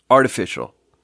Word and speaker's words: artificial: Artificial. Three syllables. Artificial. It's not three syllables.